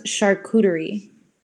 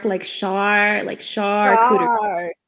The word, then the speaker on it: charcuterie: So tell me, how do you pronounce it? – So it's pronounced charcuterie. – Charcuterie. Okay, I always get it wrong. My sister-in-law and I always go back and forth on how to say it properly. So it is charcuterie. Right? – It's more like a shark, charcuterie.